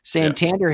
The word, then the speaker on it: Santander: Santander hits a two-run homer, bottom of the first.